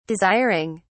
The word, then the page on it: desiring: British English